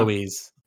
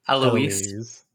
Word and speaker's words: Alois: We start off with Jacob Hawken, who is a investigator alongside Alois. How do you say that name? Do you know? Eloise. – Eloise.